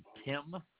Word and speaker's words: Timm: My name is Darren Tim. The last name is spelled T-I-M-M.